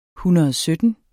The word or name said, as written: SYTTEN